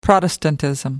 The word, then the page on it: protestantism: British English